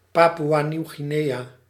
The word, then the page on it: Papua New Guinea: /ˈpæ.puː.ə ˌnjuː ˈɡɪ.ni/; /ˈpæ.pjuː.ə ˌnjuː ˈɡɪ.ni/; /ˈpæ.pu.ə ˌnu ˈɡɪ.ni/